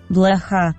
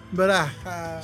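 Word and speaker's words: Blaja (блоха): ¿Cómo se dice pulga en ruso? – Blaja. Blaja. – Suena como a vieja.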